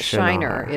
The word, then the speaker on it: Shinar: In English, you would spell it S-H-I-N-A-R. – Okay. – And it's not Shinar, it's Shinar.